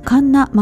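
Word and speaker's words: 神流: そして群馬県で行われましたカンナマウンテンラン& ウォーク、神様仏様の神様の神に流れると書いてカンナと読みます。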